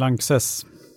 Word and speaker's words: Lanxess: Lanxess bra tack.